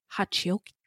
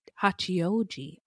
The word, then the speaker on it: Hachioji: 23 years old, she was from Tokyo, from the Tokyo suburb of Hachioki. Hachioji.